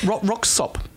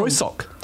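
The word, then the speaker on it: Röyksopp: With, how do I say that? Roxop. Oh. Roysok.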